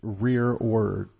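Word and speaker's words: rereward: Anyway, and he pronounced the word rearward. So it's weird because the word is actually spelled reward, but it's actually pronounced rearward.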